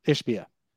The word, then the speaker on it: Ishbia: Is it Ishbia or Ishbia? How do I say it? – Ishbia.